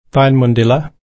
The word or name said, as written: tainmundilla